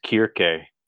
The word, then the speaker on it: Circe: But we had Circe, or however you want to pronounce the name. Kirk. – Yes. – Okay, because that's the Greek pronunciation. – The Greek pronunciation is Kirke.